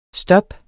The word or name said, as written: STOPPENES